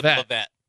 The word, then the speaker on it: Lovett: Said Lovett. Like well, Lovett. Yeah. – That's how it's supposed to be pronounced.